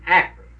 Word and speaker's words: Akron: They pronounce it with a short A rather than a long one, Akron.